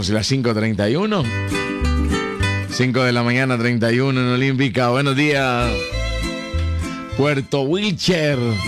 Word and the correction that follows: Wilches: Desde Puerto ¿cómo se dice? Wilcher. ¿Dice Wilches? Wilches. Hey.